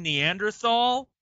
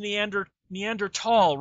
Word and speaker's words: Neanderthal: God damn it, you guys know it's pronounced Neander Neanderthal, right? That even though the T and the H are butted together, they're not pronounced together you just your scientist loses all credibility when they mispronounce common scientific words.